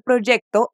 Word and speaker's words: proyecto: Mucho cuidado con la pronunciación de la palabra proyecto. Fíjate, se escribe con Y y no con J como en portugués. Además, tiene una C entre la E y la T.